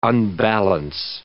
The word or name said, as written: unbalance